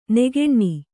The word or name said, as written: ನಗಣ